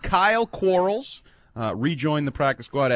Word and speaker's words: Cyhl: I mean Quarles' Kyle. – Name. – Kyle. – Kyle Quarles, defensive back. C-Y-H-L is his first name.